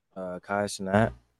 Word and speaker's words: Kai Cenat: How you say his name? – Kai Cenat.